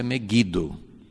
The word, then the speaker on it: Megido: Lá, assim, a pronúncia para nós é Megido, mas a pronúncia é Megido.